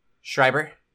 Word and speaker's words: Schreiber: Schreiber. – Yeah, in German, E-I-I-E, the second character takes the pronunciation.